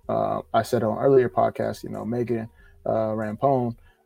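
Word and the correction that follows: Rapinoe: I always mispronounce the last name. – Rapino. – She's gonna hate me if she ever sees this. Rapino.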